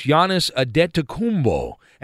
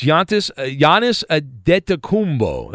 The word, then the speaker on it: Giannis Antetokounmpo: Sounds like you're using a D, though, in there. – Yes, Giannis Antetokounmpo. That's how you pronounce it.